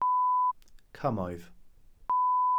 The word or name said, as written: cymoedd